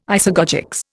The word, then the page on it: isagogics: Indian